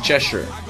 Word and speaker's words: Cheshire: Que vive no condado de Cheshire, que essas pronúncias, porque a gente, a pronúncia desses nomes britânicos aí, geralmente é assim, Cheshire.